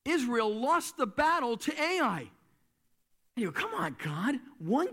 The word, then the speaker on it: Ai: It's spelled Ai, and it's pronounced Ai.